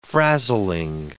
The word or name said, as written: frazzling